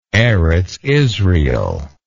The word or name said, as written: eretz israel